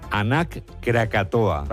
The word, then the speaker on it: Anak Krakatoa: Nos vamos a Indonesia, donde la erupción del volcán, vamos a ver si lo decimos bien, Anak Krakatoa. – ¿Lo he dicho bien? Sí. Sí, parece euskera, pero es indonesio.